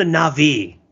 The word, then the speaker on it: Na'vi: Should I be putting the emphasis on the first or second syllable? I feel like this is one of those things where it's like, are you a human or are you a native? – First or second syllable. I would say that are they Na'vi or are they the Na'vi? – Let's call them the Na'vi. – Na'vi. All right.